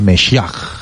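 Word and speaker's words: Meshach (משיח): en su idioma, se pronuncia Meshach.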